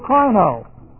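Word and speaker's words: krino (κρίνω): is the word krino. K-R-I-N long O.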